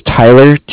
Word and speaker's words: Tyler: My name is Tyler, T-Y-L-E-R, and I'm calling... Williams. – Okay. – I'm calling with Cardiff. It's spelled C-A-R-D-I-F-F, as in Frank Frank.